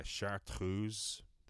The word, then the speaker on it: Chartreuse: And this one is Chartreuse, there to give it the French pronunciation.